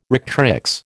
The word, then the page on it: rectrix: American English